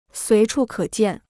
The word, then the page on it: 随处可见: suí chù kě jiàn